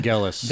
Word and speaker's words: Vangelis: Vangelis.